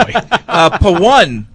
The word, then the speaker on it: Pavan: Pawan writes in from Secaucus, New Jersey. – How do we spell that? – It's spelled P-A-V-A-N, but he says it's pronounced P-A-O-N-E. – P-A-O-N-E.